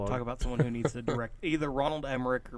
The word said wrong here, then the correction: Roland Emmerich: Roland Emmerich.